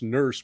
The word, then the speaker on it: Nourse: It's pronounced Nurse, but spelled N-O-U-R-S-E.